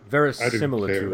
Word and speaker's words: verisimilitude: So, which is why I recommend that if you are pressed for time and you don't really, you want some historical, I can't pronounce it, verisimilitude, is that? – Verisimilitude, right.